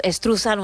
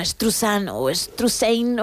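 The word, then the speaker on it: Struzan: Bueno, pues Drew Struthan o Struthane, o como cada uno lo quiera pronunciar, nosotros decimos Struthan.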